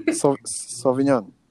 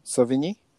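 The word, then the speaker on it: Sevigny: Can you pronounce the name, though? – Sauvigny? – Fuck, why made it so easy? I hate you. – Silent G. – Yeah, I knew that.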